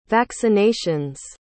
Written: vaccinations

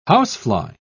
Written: housefly

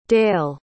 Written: dail

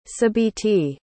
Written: sabiti

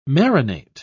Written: marinate

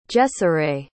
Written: Jeseray

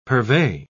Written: purvey

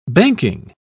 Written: banking

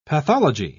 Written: pathology

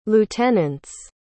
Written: lieutenants

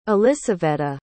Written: elissaveta

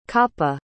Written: coppa

